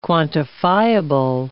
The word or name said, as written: quantifiable